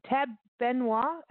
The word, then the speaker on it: Tab Benoit: And we went to the folk festival last year, and Tab Benoit, am I pronouncing this correctly? – Yeah, that's was the headliner. – That's correct.